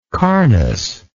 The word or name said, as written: carnous